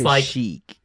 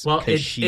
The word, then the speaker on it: Kashyyyk: Is it Kashyyyk or is it Kashyyyk? – See, I say it's Kashyyyk. – Is it Leia or Leah? – Right, that's fair. Because how it's spelled is K-A-S-H-Y-Y-K. So it could go both pronunciations technically. But, I mean, commonly it's Kashyyyk.